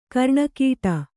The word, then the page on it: ಗರಣೆಗಟ್ಟು: garaṇegaṭṭu